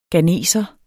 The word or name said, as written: ghaneser